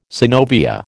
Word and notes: synovia: American English

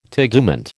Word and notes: tegument: American English

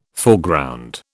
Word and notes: Foreground: British English

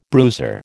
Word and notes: bruiser: American English

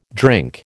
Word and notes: drink: American English

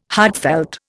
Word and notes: heartfelt: Indian

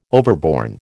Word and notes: overborne: American English

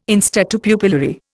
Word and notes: in statu pupillari: Indian

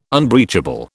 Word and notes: unbreachable: British English

